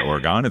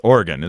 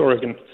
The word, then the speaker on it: Oregon: I'm mispronouncing Oregon. It's not Oregon. It's Oregon, isn't it? – That's correct. It's Oregon.